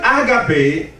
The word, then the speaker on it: agape: The first word is the word agape. Say agape. – Agape. – It's spelled A-G-A-P-E.